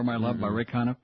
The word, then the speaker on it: Conniff: Ray Conniff, rhymes with Goniff.